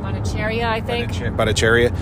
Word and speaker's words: Bhattacharya: like Dr. Jay Bhattacharya. Am I saying that right? – Bhattacharya.